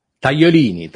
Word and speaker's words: Tagliolini: No, that's uh, Tagliolini.